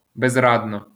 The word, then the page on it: безрадно: bezradno